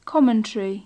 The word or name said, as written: commentary